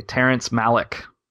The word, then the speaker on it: Terrence Malick: Terrence Malick.